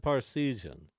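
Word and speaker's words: Parseghian: I said Parsegian. – Okay. – Which is the correct pronunciation.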